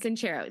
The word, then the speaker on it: Sincero: Sincero.